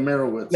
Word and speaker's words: Meyerowitz: He did like the Meyerowitz stories with Ben Stiller. I'm butchering the title of that, but yeah, the Meyerowitz.